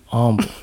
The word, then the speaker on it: humble: And be humble with the silent H. Be humble.